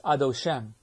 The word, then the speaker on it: Adoshem: So it's not Adoshem, it's Adoshem. It isn't Adoshem, that's Mil'el, it's Adoshem. The accent's on the last syllable.